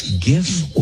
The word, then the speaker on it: GIF: See, I go with GIF because it's the hard G sound.